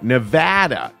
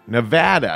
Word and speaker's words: Nevada: That is on, or no, Nevada. Nevada. I was, a fan wrote into me and said, you're pronouncing it wrong.